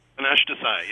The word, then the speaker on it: Panache Desai: His name is Panache Desai. Is that the way you pronounce it? – Panache Desai, yes. – Panache Desai.